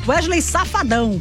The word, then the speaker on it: Safadão: Safadão, falei errado, né, gente? Ai, que horror. Wesley Safadão, pronto, falei certinho agora.